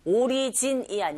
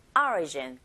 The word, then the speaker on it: origin: 나머지가 모두 다 내용 건데, origin, 오리진이 아니고 origin. 자, 앞에 철자 O의 강세가 딱 갔어요. 이런 경우에 O 소리보다는 I에 가깝게 발음이 들린다는 거 이제는 아시죠?